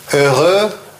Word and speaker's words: heureux: Это произносится heureux, а это malheureux. Счастливый, несчастный. Вот можете посчитать, сколько здесь лишних букв.